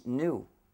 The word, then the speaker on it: GNU: Parce que selon le dictionnaire, le G est muet et le mot se prononce new, comme nouveau.